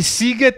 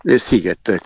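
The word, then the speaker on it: Sziget: Eh sì, SZ si pronuncia come la nostra S dolce sostanzialmente, quindi... Sighet, come sigaro appunto ti dicevo, quindi è la stessa cosa.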